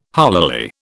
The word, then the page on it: holily: British English